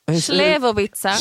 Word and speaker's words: Slivovica: Alltså jag tror att ni fick en Slivovica. – Slivovica? – Ja, exakt. – Ah, Slivovica.